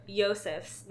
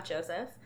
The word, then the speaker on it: Yosef: Okay, so we've been pronouncing Yosef's name is Yosef, not Joseph. And then her sister is Kate. – Okay. – It's K-E-I-T-E-H.